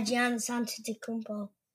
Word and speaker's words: Giannis Antetokounmpo: Yeah, Giannis Antetokounmpo.